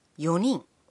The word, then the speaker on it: 四人: Four people is a bit exceptional. When 人 is added to 四, the first N sound is silent. 四人 Also, with seven people, adding 人 to 七 makes 七人 But the other pronunciation for seven, 七人 is also fine.